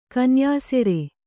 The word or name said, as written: ಕೊನೆಯುಸಿರು